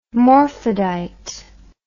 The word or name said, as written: morphadite